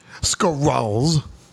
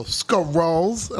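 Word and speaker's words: Skrull: I said, no, it's like skulls with an R. So she would joke around and go, skrulls?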